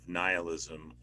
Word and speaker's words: nihilism: The best two examples of nihilism, or I suppose nihilism as some pronounce it, though I don't like that because that sounds like a guy named Neil, and then like Strong.